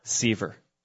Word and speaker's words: Seaver: My name is Mike Seaver, pastor. One of the pastors at Crossway Community Church, Charlotte, North Carolina. – Seaver or Sever? – Seaver. – Seaver. All right. Yeah. How do you spell that, Mike? – S-E-A-V-E-R.